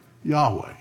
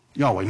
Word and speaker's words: Yahweh: So it sounds like yeah, ho, va, but it's actually the word Yahweh.